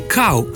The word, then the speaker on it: kal: Odgovor na vprašanje deloma ponujajo priročniki, in sicer slovenski pravopis, ki pravi: kav v pomenu mlaka bi dejansko brali kav, medtem ko sta v pomenu zelena kal, kal krompirja, klica in podobno možni obe obliki izgovorjave, kal in kav.